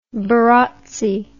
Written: barotsi

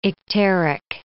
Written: icteric

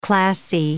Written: classy

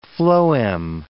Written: phloem

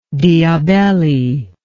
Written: diabelli

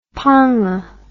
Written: ponga

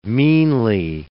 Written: meanly

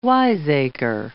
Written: wiseacre

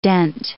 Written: dent

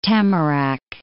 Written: Tamarac

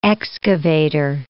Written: excavator

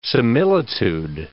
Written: similitude

